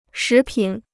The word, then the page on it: 食品: shí pǐn